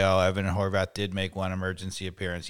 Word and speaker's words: Horvath: We've got Sean Johnson, who you presume is, you know, this third or second Ethan Horvath, or how do you pronounce Horvath. it? Horvath. Horvath.